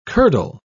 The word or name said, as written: curdle